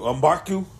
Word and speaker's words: M'Baku: M'Baku? – Yeah.